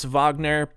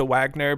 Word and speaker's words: Wagner: George is of German descent, so it could be actually pronounced Wagner, but it is for sure morphed into Wagner by now, so if you don't like it, you can get out.